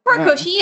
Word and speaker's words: Horikoshi: Horikoshi, yeah.